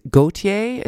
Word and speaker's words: Gotye: And that would suggest to me that, is Gotye is how it's pronounced? – Yes. Yep.